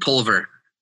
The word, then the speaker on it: Pulver: Yeah, it's Clint Pulver.